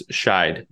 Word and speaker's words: Scheidt: It's Scheidt.